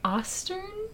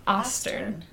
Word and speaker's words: Ostern: O-S-T-R-T-E-R-N. – Ostern. Okay, I would think Ostern. That. – Because there's Western. Uh-huh. And then there's Ostern.